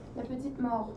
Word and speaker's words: petite mort: Petite mort? No, something else. Mort. How do you say it? – Petite mort. Yeah, small death.